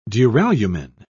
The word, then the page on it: duralumin: British English